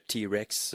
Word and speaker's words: TRX: L'artiste s'appelle T-Rex. Je vous fais entendre sa pièce « Someone has erased my memories ». Thank you. De la Bulgarie, c'était T-Rex, ça s'écrit T-R-X, donc c'était sa pièce « Someone has erased my memories ».